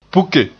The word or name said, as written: puke